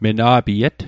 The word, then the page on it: mena'piet: Mi'gmaq